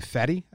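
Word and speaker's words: Fedde: Like, their backup catcher and their sixth starter, who's going to be Fetty, I don't know if you pronounce that right. – Yeah, you got it.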